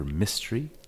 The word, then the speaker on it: Mistri: I am Dr. Mystery. – M-I-S-T-R-I.